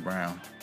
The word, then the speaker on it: Braun: They pronounce it Brown. – Brown, that's what it is.